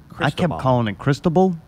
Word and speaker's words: Cristobal: How do you say it, Mayday? – Cristobal? – That's you? – It's Cristobal.